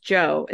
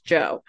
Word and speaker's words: DJO: I think it's just Joe. It's spelled D J O. So, but I think it's just Joe.